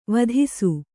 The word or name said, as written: ವಧಿಸು